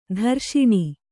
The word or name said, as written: ದುರೇಷಣೆ